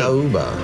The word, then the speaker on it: Macaúba: What is Makauba. – Can you spell it? – I hope I said that correctly. – Makauba. – Makauba. – M-A-C-A-U-B-A.